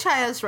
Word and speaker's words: Chaya: No, I think Chaya's right. – Okay, good. Yeah. Because for some reason, I just kept saying it wrong this past week. – Wait, Nixie? No, never. – No, me? – Never. No, Chaya sounds right to me.